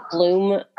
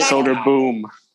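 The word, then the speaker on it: Söderblom: And even Soderblom, although he's in the Soderblom, I think. Blom? I don't know. – They pronounce it Bloom. – Soderblom. Yeah. – Okay. Soderboom, like Adam Cole.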